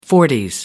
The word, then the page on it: forties: American English